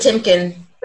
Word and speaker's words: Patinkin: yeah, because Mandy Pat Patikin, he didn't. – He Mandy didn't, Patinkin.